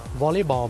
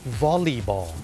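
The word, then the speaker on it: volleyball: วอลเลย์บอลไปแล้วเนี่ยคนไทยเนี่ยเมื่อพูดถึงกีฬาวอลเลย์บอลเนี่ยออกเสียงว่าวอลเลย์ถูกไหมครับวอลเลย์บอลแต่จริงๆแล้วนะครับเจ้าของภาษาเนี่ยไม่ได้ออกเสียงว่าวอลเลย์บอลนะครับเขาออกเสียงว่าวอลลีบอลวอลลีบอลตัว L E Y เป็นออกเสียงลี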